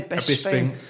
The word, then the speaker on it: Bisping: Bisping.